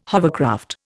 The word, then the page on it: hovercraft: Indian